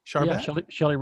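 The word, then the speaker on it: Shelle: certified language and behavior consultant for Shell Rose Charvet. – Shelley Rose Charvet.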